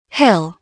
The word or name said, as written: hill